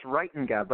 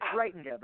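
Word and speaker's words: Reitenga: It's pronounced Reitinga, but it's Reitinga, but it's a really hard one.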